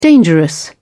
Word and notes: dangerous: British English

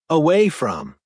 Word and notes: away from: American English

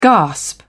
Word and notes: gasp: British English

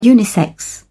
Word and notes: unisex: British English

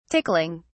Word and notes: tickling: British English

